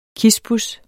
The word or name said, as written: kispus